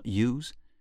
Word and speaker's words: ewes: Avec le lait des brebis, j'imagine que c'est ce que veut dire ewes, qui se prononce ewes, il fabrique du fromage.